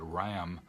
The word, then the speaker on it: Ramm: and we have people like Bernard Ramm, German probably is pronounced Ram, but R-A-M-M,